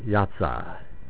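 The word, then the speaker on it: Yahtzeh (יָצָא): Hatza. Wait a minute. Am I saying that right again? Now, where'd that go? pronounced Yahtzeh. Okay, Yahtzeh. That's right.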